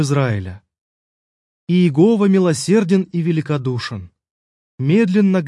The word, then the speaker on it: Иегова: По-русски оно обычно произносится как «Иегова».